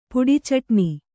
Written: ಪುಡಿಚಟ್ಣಿ

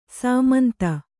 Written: ಸಮಂತು

